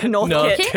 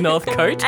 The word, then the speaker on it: Northcote: Now, Morky, I don't want to shame you for being a Perthian here, but I do believe it's pronounced Northcote. – Instead of Northcote.